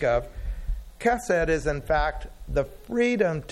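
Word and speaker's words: chesed (חסד): The biblical word that is used in the Old Testament to describe this is chesed. We translate that many different ways, but most often as loving kindness. It's spelled appropriately with an H, but you pronounce it like you would Christmas with a CH.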